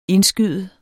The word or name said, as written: indskyde